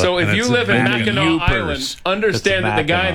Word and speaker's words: Mackinac: It's Mackinac.